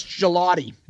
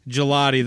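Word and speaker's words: Gugliotta: I want to say Googliata because we had a Tom Googliata that played for the Timberwolves. – The Minnesota Timberwolves. Yeah. Tom Googliata. It's Gelati. – Gelati. That meant it definitely is spelled more like a gelati.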